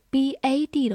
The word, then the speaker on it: bad: 好，最后注意一个发音的对比，如果我们是 b a d 的话，字母 a 应该发大口的。变成 bad，bad，它的含义是形容词坏的，所以注意它们两个发音的对比，坏的 bad，床 bed。